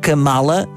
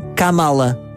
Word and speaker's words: Kamala: Não se, não se pronuncia Kamala, mas sim Kamala.